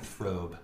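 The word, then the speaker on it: Fröbe: And as we mentioned earlier, it was Gert Frobe. I'm assuming that's how you pronounce it. If it's got an umlaut over the O, it makes it a long O sound. I'm going with Frobe.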